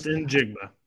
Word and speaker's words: Njigba: Number 12, Jackson Smith Njigba. I cannot pronounce his name. I will never be able to. – Just Njigba. Just Njigba. – Wow, it's so phonetical, too, which is the worst part.